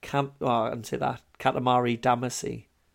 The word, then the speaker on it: Katamari Damacy: Katamari Damacy.